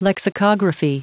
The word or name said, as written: lexico